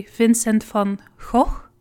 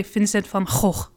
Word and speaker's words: Vincent van Gogh: So in the south, they would say Vincent van Gogh. And up north, we say Vincent van Gogh. So it's a bit less flattering up north, I guess, the way we pronounce it.